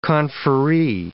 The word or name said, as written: conferee